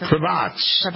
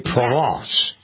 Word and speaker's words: Provence: Provence, is that how you say it? – Provence. En français, Provence. Yes.